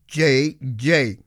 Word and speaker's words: DjeDje: J.J. Her last name is spelled D.J.E.D.J.E.